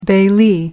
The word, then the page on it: bailee: American English